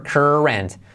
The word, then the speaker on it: current: Cuidado con la pronunciación, current. R genérica, da igual que haya una U, una A, una E, una I, no está, no hay nada, la letra clave R, current.